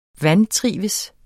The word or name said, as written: VANTRIVES